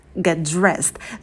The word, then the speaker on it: get dressed: Get dressed. Звук «т» в слове «get» мы можем не произносить, потому что у нас на стыке много согласных. Можно сказать просто «get dressed».